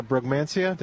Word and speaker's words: Brugmansia: Yeah, and I know you talked about the woman who was buying the Brugmansia.